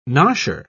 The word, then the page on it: nosher: British English